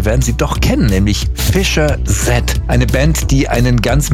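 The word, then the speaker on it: Fischer-Z: Ganz einfach, die Band war sehr lustig drauf und hat sich gedacht, wir nehmen einen Namen, der so ähnlich wie Fish's Head klingt. Ausgesprochen Fischer Z, ja, also Fischkopf.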